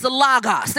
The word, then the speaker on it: logos: I was saying that wrong my entire life. I had to hit the little pronounce this thing in the blue letter Bible to tell me how to correctly pronounce it. So it's logos.